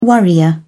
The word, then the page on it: worrier: British English